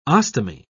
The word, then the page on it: ostomy: British English